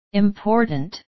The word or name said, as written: important